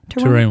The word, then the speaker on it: terrine: Did I say that right? Terrine? – Terrine will do.